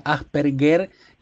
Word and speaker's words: Asperger: Una es que se pronuncia Asperger y no Asperger, como venía diciendo yo, ¿no?